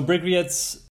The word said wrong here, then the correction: Bryggeriet: how do you pronounce it properly? Actually, how would you say it in Swedish? – Bryggeriet. – Bryggeriet.